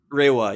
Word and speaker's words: Reiwa: Reiwa? – Reiwa, yeah.